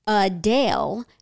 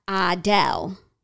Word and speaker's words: Adele: The correct pronunciation is Adele, not Adele.